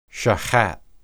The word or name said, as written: shahath